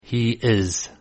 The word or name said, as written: he is